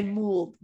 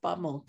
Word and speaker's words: Mould: I, I, always want to say Mould, but it's Bob Mould.